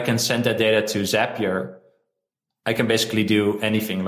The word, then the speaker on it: Zapier: So you could connect a form on your Carrd website with Zapier uh, or Zapier. I have to say Zapier because it rhymes with happy.